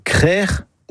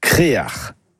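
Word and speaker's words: krec'h: Le mot breton, c'est créard. Et ce mot, on le retrouve dans des hameaux ou des quartiers, même pour un phare. Et la bonne production est crère ou créard. Créard, c'est une prononciation plus du Nord Finistère, mais on la trouve parfois ailleurs.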